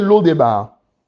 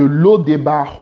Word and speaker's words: Lodebar: Maintenant, à, cette étape de l'audio, maintenant, tu écris de l'eau des L'eau des ça s'écrit L-O-D-E-B-A-R. Lis un peu ta Bible. Amen. L'eau des de l'eau des barres au palais, du désert au pâturage, de l'eau des barres au palais, du désert au pâturage, du désert au palais.